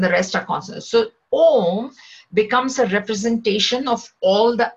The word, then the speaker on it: Om: Okay, now when you see Om, it consists of three syllables, A, U and Ma. A, U and M, if we take the English listening, A and U are vowels and M is a consonant.